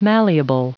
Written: malleable

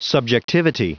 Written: subjectivity

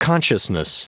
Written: consciousness

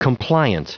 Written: compliant

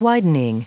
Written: widening

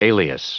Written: alias

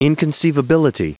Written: inconceivability

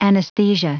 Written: anesthesia

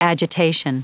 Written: agitation

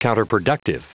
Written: counterproductive

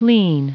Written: lien

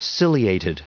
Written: ciliated

